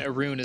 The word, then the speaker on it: Arun: And Arun is spelled A-R-U-N. – Yep. – Like the color.